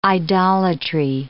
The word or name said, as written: idolatry